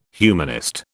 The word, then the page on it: humanist: British English